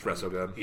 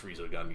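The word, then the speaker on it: Resogun: It's Rezogun. – Yeah, it's not pronounced Rezogun, you fucking maniacs. There's only one E.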